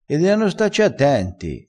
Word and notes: deano: dialettale

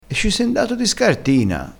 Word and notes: di scartina: dialettale

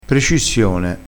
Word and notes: precissione: dialettale

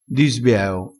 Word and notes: di sbieo: dialettale

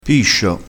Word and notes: piscio: dialettale

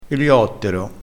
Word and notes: eliottero: dialettale

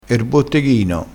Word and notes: botteghino: dialettale

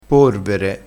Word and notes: porvere: dialettale

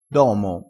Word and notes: domo: dialettale

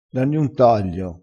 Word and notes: danni un taglio: dialettale

